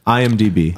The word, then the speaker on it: IMDb: You know, real shit, and they went all the way as far as to get on, like, you know, IMDb and all that kind of stuff. – Fuck yeah. – Did I say that right? – IMDb. – IMDb.